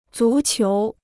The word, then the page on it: 足球: zú qiú